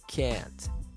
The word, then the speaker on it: can't: Você vê que tem a contração e aí, geralmente o pessoal se enrola nessa pronúncia, mas um segredo é você pronunciar com o T bem fraco, quase desaparecendo. Can't, can't.